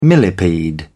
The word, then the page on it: millepede: British English